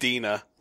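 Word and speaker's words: DeNA: Which is spelled Dina. – Yeah, Dina.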